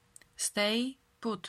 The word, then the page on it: stay put: slow